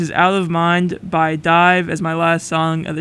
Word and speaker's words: DIIV: Alright, that was Out of Mind by D-I-I-V, pronounced Dive.